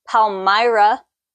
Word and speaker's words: Palmyra: Palmyra.